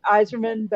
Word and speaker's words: Eiserman: And even though it's pronounced Iserman, but it's spelled E-I-S-E-R-M-A-N.